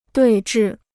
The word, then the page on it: 对峙: duì zhì